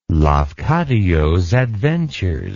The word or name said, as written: Lafcadio's Adventures